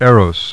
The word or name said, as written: Eros